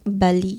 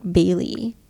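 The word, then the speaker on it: Bally: It's by the way it's pronounced Bali not Bailey.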